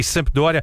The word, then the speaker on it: Sampdoria: Sampdoria Tá certo, tá certo.